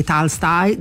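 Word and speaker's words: Tolstoi (Толстой): Allora, intanto, per far capire che uno sa parlare con la pronuncia, la O in russo diventa A, e quindi Tolstoi, Dostoevsky, eccetera.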